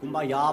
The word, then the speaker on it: Kumbaya: Kumbaya vol dir vine aquí. Els negres, en comptes de dir come by here, pronuncien Kumbaya, vol dir vine cap aquí, senyor.